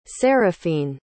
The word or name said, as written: seraphine